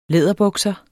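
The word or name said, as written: LÆDERBUKSER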